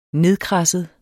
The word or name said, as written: nedkradset